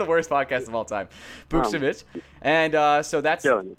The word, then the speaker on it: Buchnevich: We still haven't signed Pavel Buchnevich. I can't say his name. I know his first name is Pavel. – It's Buchnevich.